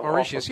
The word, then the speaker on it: Mauritius: So just all of a sudden, Karis Teton, who is from Mauritius, is that how you pronounce it? The island off the coast of Africa? – Or Mauritius, of, yeah. More or less, yeah.